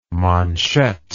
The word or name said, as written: manchette